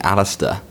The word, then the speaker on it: Alistair: My name's actually Alistair, but it's spelt in a ridiculously stupid way.